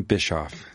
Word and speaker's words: Bischoff: Well, now, in an April 5th letter to Lisa Thompson, Harvey Bischoff, president of OSST, am I pronouncing his name? – That's his name, Bischoff.